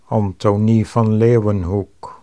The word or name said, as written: Anthonie van Leeuwenhoek